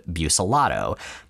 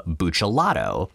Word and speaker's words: Buccellato: His family always pronounced it Buscellato. But after our current coverage, in 1998, he actually moved to a more standard Italian pronunciation of Buccellato. He had been pronouncing Buce, I guess it should technically be Buch, as Buce.